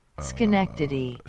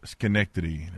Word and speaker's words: Schenectady: Schenectady. – Schenectady. There you go. Schenectady. I want to say Schenectady. Schenectady. – Okay. Schenectady. – Shout out to the new New York affiliate in Amsterdam, Schenectady, for coming on and having my show affiliated.